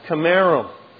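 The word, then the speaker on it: kemarim: It's actually, if you were to read the Hebrew, it would be pronounced Chimerim.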